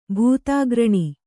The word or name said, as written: ಭೂತಾಗ್ರಣಿ